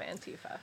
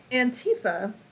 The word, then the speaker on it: Antifa: What is the definition of Antifa? – So, Antifa, and so I guess I should say I have always pronounced it Antifa, but I know that people pronounce it Antifa right now.